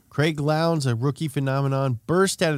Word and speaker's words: Lowndes: There was also a new generation of drivers, including Mark Skeif and Craig Lowndes. – Rhymes with clowns.